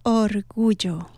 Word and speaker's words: orgullo: Well, say it again. – Orgullo. – Orgullo? – Sí, perfecto.